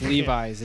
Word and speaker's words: Levi's: So this song, Lives. – I think it's just my own. – It's Levi's. It's actually it's them being corporate capitalist sellouts even more. It's Levi's is actually how it's pronounced.